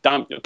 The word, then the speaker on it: daimyo: Is that, did I say it right? Daimyo? – Daimyo.